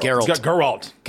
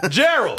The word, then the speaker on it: Geralt: Geralt. Geralt of Rivia. – Geralt. See, there you go. That's why you don't understand shit. You can even say his name. – Geralt. – Gerald.